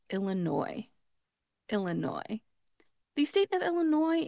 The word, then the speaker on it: Illinois: However, in this case, because it does come from a French word, we do not pronounce that. Instead, we are going to leave off that final S and just pronounce it Illinois.